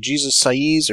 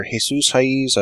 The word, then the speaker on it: Jesus Saiz: Artist is Jesus Saiz, or Jesus Saiz. I mispronounced the first name this time.